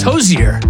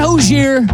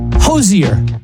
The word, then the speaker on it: Hozier: So just think of it as Hozier, add an H. So it's Hozier. Not Hozier. Hozier. They sound kind of similar, don't they?